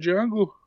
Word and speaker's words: Django: Fucking Django. – The big D. – Aye. – Well, J, because the D is silent.